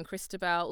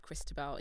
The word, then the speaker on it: Kristabel: My name is spelled K-R-I-S-T-A-B-E-L. So yes, social, I am Christabel. Also on YouTube, just type Christabel into YouTube.